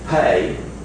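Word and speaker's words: pe: This is the fay. It can be pronounced fay or pay.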